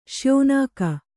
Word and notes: ಶ್ಯೋನಾಕ: śyōnāka

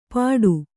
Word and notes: ಪಟು: Kannada

